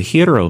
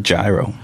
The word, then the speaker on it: gyro: It looks like if you've ever been to a Greek restaurant, it looks like the hero meat is stacked up like that. And then there's the pineapple on top. – I always read it gyro. So it's hero.